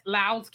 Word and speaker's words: Lowndes: This is, you see, it's called Bloody Lowes. That's how it's spelled. L-O-W-N-D-E-S, Lowes County.